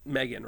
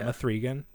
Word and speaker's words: M3GAN: I mean, Megan, right? – Oh, is that how you say it? It's not Mithrigan? Because it's written with a three.